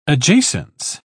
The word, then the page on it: adjacence: British English